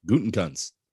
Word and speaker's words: Gutekunst: You try saying that. – Gutekunst. – Yeah, I said it right the second time. – Yeah, not the first time.